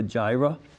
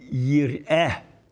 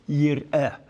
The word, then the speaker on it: Jireh: There's a hymn that talks about God as Jehovah Jireh. It would be Yireh, not Jireh. No. Yireh, which means he will see to it and in that sense provide, you know.